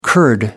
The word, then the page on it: kurd: American English